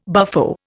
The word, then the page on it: buffo: Indian